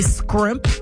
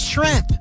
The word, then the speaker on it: shrimp: Now, for me, when someone can't say scrimp, or they say scrimp, like, act like you've had it before. It's shrimp. S-H-R-I-M-P.